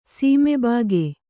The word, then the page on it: ಸೀಮೆಬಾಗೆ: kannada